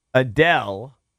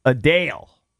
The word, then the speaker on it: Adele: It's not Adele. – Okay. – It's Adele.